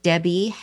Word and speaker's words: Debby: So that's under my name, Debby Handrich. Debby is spelled D-E-B-B-Y, and Handrich is H-A-N-D-R-I-C-H, Debby Handrich.